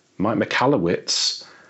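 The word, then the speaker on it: Mike Michalowicz: Mike McAllowitz, am I pronouncing it right? – You actually did. It's actually beautiful with the British accent. So I pronounce it McAllowitz, like you said.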